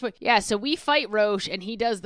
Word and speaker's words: Roche: His name is Roche, R-O-C-H-E.